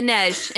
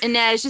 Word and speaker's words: Inej: Okay, Inej. Inej is how you say it.